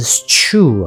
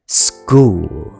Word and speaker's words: school: 那如果照样这样来讲，s c h o o l，学校应该是 school 啊。那为什么它念 school？因为 c h 它也有另外的一个发音方式，叫做。所以学校的 s c h 它的发音会变成 sk sk 变成 school。